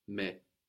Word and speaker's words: mais: But in French is spelled M-A-I-S. How might that sound? M-A-I-S. – Mais. – Very good. Mais. A-I gives us an E sound, like in maison, house, like in nécessaire, which has the A-I-R-E ending.